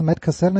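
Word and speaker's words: Cassel: Meine Frage ist natürlich, was hat Matt, Cassell, äh, Matt Castle verbrochen, das ist Matt Castle, ich glaube Matt Castle spricht man aus,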